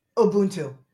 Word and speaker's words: Ubuntu: Ubuntu.